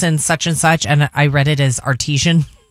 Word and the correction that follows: artisan: Oh, okay. – Somebody called her like, I think it's artisan. I kept saying the artesian blah, blah, blah. – You were mispronouncing.